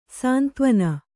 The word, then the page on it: ಸಂತವಣ: santavaṇa